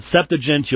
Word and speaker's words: Septuagint: Now, some people pronounce that Septuagint. I pronounce it Septuagint.